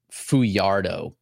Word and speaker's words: Fajardo: And then Fajardo is F-A-J, excuse me, A-R-D-O. So that's, let me pronounce it right, Yoker Fajardo, Boston Red Sox prospect.